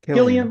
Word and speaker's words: Cillian: Is it Cillian Murphy? Cillian Murphy.